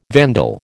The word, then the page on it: vandal: American English